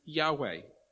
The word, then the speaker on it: Yahweh: Some older translations might use the word Jehovah. But those in the scholarly world, those in the know, those who are paid for this stuff, they reckon it's most probably pronounced Yahweh.